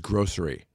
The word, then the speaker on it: grocery: What is correct in the only pronunciation given in the American Heritage Dictionary is grocery. There is no SH.